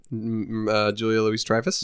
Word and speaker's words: Louis-Dreyfus: Is it Julia Louis-Dreyfus? – Yes, Dreyfus.